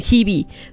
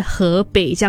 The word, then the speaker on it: Hebe: 就是我的表哥他想要听，他很喜欢 Hebe，但是他也不知道他的 Hebe 就是读成 Hebe，他因为他是读汉语拼音的嘛，所以他就把他当成是河北这样子。然后呢，有一次我们去台湾的时候，我们就经过了 Hebe 的那一个海报，走了一段路了之后呢，他才跟我说，欸，其实我很喜欢的那个女歌手，你们有没有去听她的歌曲？